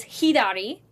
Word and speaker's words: hidari: So to the left is hidari. – Hidari. – Which is H-I, for Romaji, it's H-I-D-A-R-I. So hidari. – Hidari. – And to the right is migi. – Migi. – M-I-J-I.